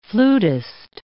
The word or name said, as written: flutist